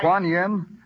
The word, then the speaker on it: Kuan Yin: K-U-A-N-Y-I... – Kuan Yin? – Yeah, that's it.